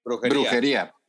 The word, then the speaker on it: Brujería: Brujilla, is that, did I say that correctly? – Brujería. – Yeah, Brujería. Yes, there you go.